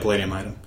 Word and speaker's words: Palladium Item: What level of, what's your formal beat at the Palladium Item? Did I pronounce that right? – Yeah, Palladium Item.